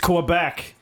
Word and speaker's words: Quebec: Can you please pronounce that city correctly? – No. – It's Quebec.